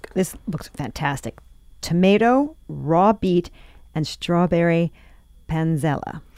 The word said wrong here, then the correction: panzanella: Panzanella. Yes. – I lost a syllable in there.